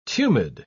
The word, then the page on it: tumid: British English